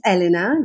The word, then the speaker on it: Elena: So my name is Elena. That's how we pronounce it.